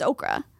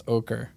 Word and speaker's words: ochre: It's Okra. – Yeah, sure, whatever. – But it's not supposed to be that. – It's supposed to be pronounced ochre.